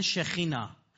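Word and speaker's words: شخینا (שכינה): برید شما منابع یهودی رو بخونید، به سکینه را به عبری سه و شه تلفظ میکنن، کاف رو هم خه تلفظ میکنن، میگن شخینا.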